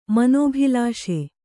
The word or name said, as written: ಮನೋಭಿಲಾಷೆ